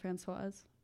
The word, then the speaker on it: Francoise: and I have gone by Francie my whole life because most people just couldn't pronounce Francoise.